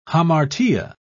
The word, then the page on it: hamartia: British English